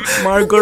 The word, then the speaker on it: Margot: M-A-R-G-O-T. Silent T pa. Margot or Margo. Di ba Margo? – Margo.